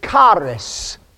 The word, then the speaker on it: charis: And the Greek word for grace is charis. It's spelled C-H-A-R-I-S or K-A-R-I-S. Charis.